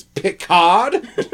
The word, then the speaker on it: Picard: You were in the emphasis on the wrong syllable. – I think it's Picard.